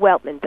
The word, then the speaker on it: Weltman: Barbara, usual spelling, Weltman, W-E-L-T-M-A-N.